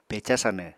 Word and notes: peca sanw: Apatani

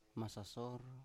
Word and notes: masasor: Biak

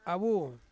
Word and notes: awu: Ansus

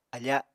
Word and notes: afa: Apatani

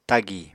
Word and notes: tagì: Apatani

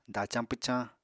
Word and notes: dacañ pwcañ: apatani

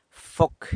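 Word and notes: foc: San Lucas Quiaviní Zapotec

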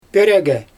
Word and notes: pörIgI: Xyzyl

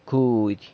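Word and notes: cudy: San Lucas Quiaviní Zapotec

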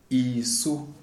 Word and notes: Isu: Shona

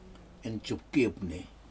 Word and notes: injupki upni: Aneityum

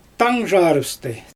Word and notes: taangʒarɯpstɯ: Xyzyl

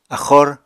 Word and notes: axor: apatani

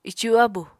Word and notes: icu-abu: apatani

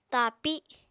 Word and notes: tapi: Apatani

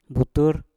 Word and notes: butur: Mpur

